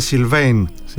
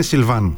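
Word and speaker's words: Sylvain: Θα τον ακούσεις στην Ευρωλίγκα που έχει τα ονόματα των παικτών να το προφέρει αμερικάνικα, δηλαδή Σιλβέιν Φρανσίσκο, αλλά το γαλλικό είναι Σιλβάν.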